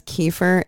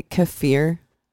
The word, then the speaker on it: kefir: That's like when I went around, because Kiefer, you know, what everyone pronounces as Kiefer is actually Kefir.